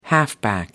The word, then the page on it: halfback: American English